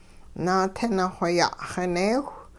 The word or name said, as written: naatinixwe ya'xineewh